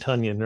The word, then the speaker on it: Tonyan: Funyan Tonyan, right? Yeah, I always got to remember it rhymes with Tonyan. Funyan.